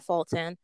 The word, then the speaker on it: Fulton: It's at the Kiana Fulton. It's spelled Kiana, spelled Q-U-I-A-N-A, and then my last name, F-U-L-T-O-N.